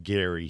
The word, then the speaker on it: Gerry: If you could back up a minute, the term gerrymandering itself is traced back to Elbridge Gerry, or as he pronounced it, Gary. He pronounced it with a hard G, but over time— But we've changed that.